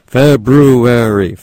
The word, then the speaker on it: February: The second month of the year, February, you know, has two R's, so pronounce them. February.